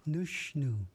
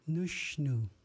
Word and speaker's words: Nushnu: In Ichishkin or Warm Springs, nose is said Nushnu. Nushnu.